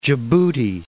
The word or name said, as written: Djibouti